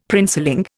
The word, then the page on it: princeling: Indian